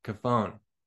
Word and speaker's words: Cofone: So, Joey Cofone, am I saying that right? Cofone, yeah, you got it.